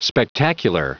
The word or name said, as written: spectacular